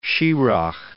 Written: Schirach

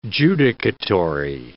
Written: judicatory

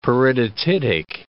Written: peridotitic

